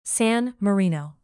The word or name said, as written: san marino